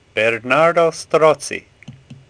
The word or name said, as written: Strozzi